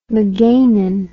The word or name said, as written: magainin